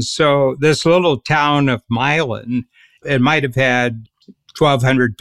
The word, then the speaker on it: Milan: It was called Milan, same as Milan, Italy, but it's pronounced Milan.